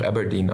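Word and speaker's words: abrdn: Äh, ist, äh, die Schreibweise ist ungewöhnlich, aber man spricht tatsächlich einfach Aberdeen aus. – Ah, okay. – Äh, wie die Stadt.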